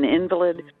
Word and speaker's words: invalid: Or, you know, the word invalid. – Mm-hmm. – I am an invalid. But if you put the accent on the other, on the middle syllable, it's invalid.